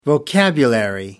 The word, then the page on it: vocabulary: American English